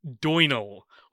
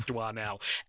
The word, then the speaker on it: Doinel: this fortnight we were given from our lovely patrons the Adventures of Antoine Doinel, or as we soon found out, Doinel, because of course it's Doinel. Antoine has O-I in it, and we pronounce that fine. We get to Doinel, and we're like, yeah, that's how it'll be pronounced, Doinel.